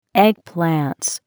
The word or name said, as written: Eggplants